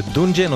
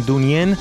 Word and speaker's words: Dungen: הרכב הפסיכדלי המקסים משוודיה, דוניאן, כותבים את שמו D-U-N-G-E-N, שנדמה שזה דונג'ן או דנג'ן, אבל לא, אומרים דוניאן.